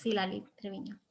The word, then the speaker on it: Citlali Treviño: It's a great children's book, like I said, titled Toma, Toma, Toma, with brilliant artwork as well by the artist Citlali Treviño. Am I saying her name correctly? – Yes, Citlali Treviño.